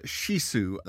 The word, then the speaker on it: Chisou: I took her to Shisu, that's spelled C-H-I-S-O-U, and that's because I want you to find it.